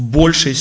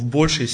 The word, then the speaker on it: большей: Дух Святой вовлечен в нашу с вами каждодневную жизнь в большей степени, не в большой степени, а ударение на букву О, в большей степени.